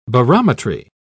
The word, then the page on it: barometry: British English